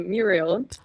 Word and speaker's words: Muriel: Chandler Bing heißt mit seinem Middle Name Muriel. So, hi. – Stimmt! Ja, wie geil. – If anybody's questioning how to pronounce my name, there you go.